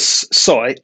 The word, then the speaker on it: Sooy: First, my name is Soy, S-O-O-Y.